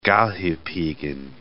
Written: Galdhopiggen

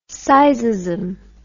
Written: sizeism

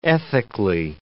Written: ethically

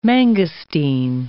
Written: mangosteen

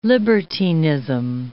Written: libertinism

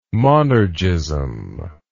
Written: monergism

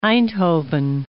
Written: Einthoven

